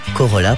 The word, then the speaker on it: Corolla: Voilà un Ainsi s'achève ce concert avec Oui FM et la nouvelle Toyota Corolla. Corolla, pardon. J'écorche le nom du sponsor.